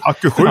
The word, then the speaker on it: Hakushu: Eh, vi pratade om hur man uttalade, ja jag vågar knappt göra det, hakushu. – Ja, typ, det är kanske, typ så. Ja. Kanske. Hakushu. Ja. – Hakushu, ja. Exakt.